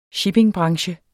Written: shippingbranche

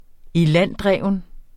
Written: ilanddreven

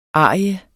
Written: ariens

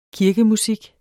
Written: KIRKEMUSIK

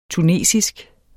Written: tunesisk

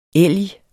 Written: elg

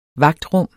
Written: vagtrum

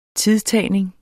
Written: tidtagning

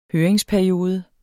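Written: høringsperiode

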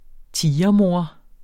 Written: tigermor

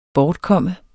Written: BORTKOMMEN

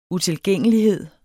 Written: utilgængelighed

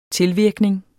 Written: tilvirkning

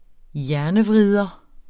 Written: hjernevrider

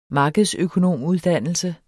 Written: markedsøkonomuddannelse

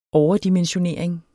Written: overdimensionering

